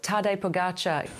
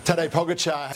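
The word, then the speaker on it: Pogačar: Slovenia's Tadej Pogacar. – Tadej Pogacar.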